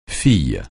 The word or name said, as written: fille